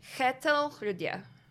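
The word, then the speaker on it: Kjetil Rødje: Kjetil Rydje. It's spelled K-J-E-T-I-L, R, O with the line through it, D-J-E.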